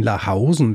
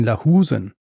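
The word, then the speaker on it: Lahousen: Tatsächlich war das nicht Erwin Lahausen, wie ich ihn ausgesprochen habe, sondern Erwin Lahusen.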